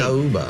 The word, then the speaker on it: Macaúba: Macaúba. – Macaúba. – It's hard when those vowels are dancing with one another, the A and the U.